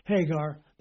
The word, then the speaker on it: Hagar: The one from the Mount Sinai, which gendereth to bondage, which is, put an H in front of the A and you'll pronounce it right, Hagar. There's a rough breathing in the Greek. The translators ignored it, and that gives it an H sound.